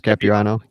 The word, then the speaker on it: Capuano: Anybody know? – Yeah, Chris Capuano. You got it.